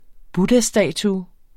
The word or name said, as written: buddhastatue